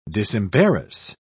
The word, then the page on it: disembarrass: British English